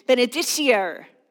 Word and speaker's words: benedicere: Benedicere.